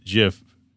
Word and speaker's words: GIF: In 2012, Oxford American Dictionary's named GIF its U.S. Word of the Year, accepting both pronunciations. But in 2013, Wilhite flat out told New York Times it's soft G pronounced GIF.